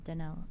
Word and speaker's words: Danel: Just to say it right, yeah. – I'm sorry. – D-A-N-E-L, Danelle.